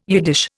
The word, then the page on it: Yiddish: Indian